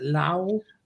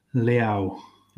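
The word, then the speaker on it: Leão: We've got a lad from AC Milan that we're going to come to you on, which is it Rafael? Is it Lau? – Liao. – Liao. – I think I'm saying that right. We'll get input on that. – Liao. I was going to say, What is he? – Yeah, Rafael Liao. That's right.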